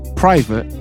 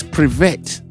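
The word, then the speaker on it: privet (привет): You say private? Nah, you can't say private. Oh, it's privet.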